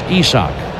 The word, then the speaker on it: Isaac: First name looks like it'll be pronounced Isaac, I-S-A-A-C. Pronounced Isak, Isak Paredes.